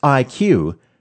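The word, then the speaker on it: IKU: It's pronounced IQ, but it's spelled I-KU, as in Ku-band.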